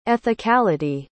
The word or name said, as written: ethicality